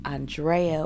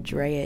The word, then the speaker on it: Andrea: I am your jet lagged host, Andrea, or Drea if you butcher the A-N of my name.